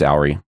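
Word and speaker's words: Auri: Owry, help me, is that the right pronunciation? – Mm-hmm. Yeah, it's Owry.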